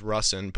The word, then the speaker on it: Rusin: Dude, I'll jump on podcasts all the time, and people always butcher my name because, you know, Russin and Rusin, it's said totally different than it's spelled,